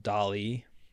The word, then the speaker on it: DALL-E: Was this AI art engine called DALL-E, and it's spelled like WALL-E, except with DALL-E.